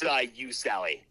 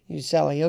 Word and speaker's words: Ucelli: Talking with Gerard Urcelli of, is it Ucelli? Ucelli? How do you say it? – It's Ucelli. – Ucelli, okay.